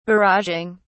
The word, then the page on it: barraging: British English